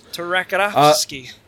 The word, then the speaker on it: Tartakovsky: Tartakovsky.